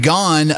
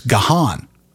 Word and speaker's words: Gahan: It's spelled G-A-H-A-N. I had always figured it was Gahan. And apparently there's no huh in the middle of Gahan.